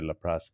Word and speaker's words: Laplace: Laplace, yes.